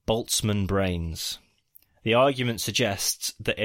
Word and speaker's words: Boltzmann: Boltzmann.